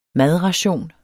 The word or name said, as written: madration